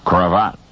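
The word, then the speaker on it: cravate: But in French, it is pronounced cravat. The cravat.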